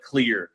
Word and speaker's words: Klyr: want to talk to you about clear rum.